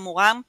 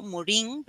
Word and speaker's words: Morin: En ese caso, eh, traigo a colación a Edgar, bueno, creo que en francés se pronuncia Morin, pero en castellano vamos a leerlo Morin, ¿verdad?